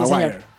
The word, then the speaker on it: Maguire: Usted que es un hombre que pronuncia también en inglés, Maguire. Maguire, sí, Maguire.